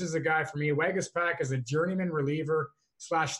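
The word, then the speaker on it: Waguespack: I actually went before the show and I tried to figure out how to say this dude's name from Toronto. Jacob Wagaspak, I believe is how we pronounce that, drawing the starts.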